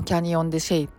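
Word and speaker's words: Canyon de Chelly: ナバホの人の言葉とかで言うとシェイっていうあの発音に近いみたいでねまあ、キャニオン・デ・シェイっていうんですけど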